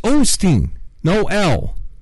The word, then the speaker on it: Osteen: Now it's Osteen, no L.